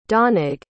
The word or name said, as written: donnag